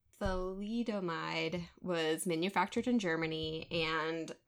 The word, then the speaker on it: thalidomide: Oh, thalidomide.